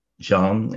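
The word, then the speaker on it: Dzyan: Het is inderdaad dat boek van Zian, of Zian is het inderdaad, het is een lange lettergreep.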